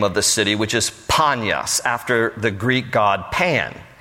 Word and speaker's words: Panias: But the Arabs can't, in Arabic language, they cannot pronounce P. Panyas. They can't say the letter P, so it is called Banyas with a B.